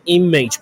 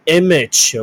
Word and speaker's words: image: image，I M A G，有人会念成 image，不对，那个 A 是发短音的 E，很不规则的发音，所以来跟我念一次 image，image。 OK，so Taiwan model Lin Chiling reposted "I love you China" image。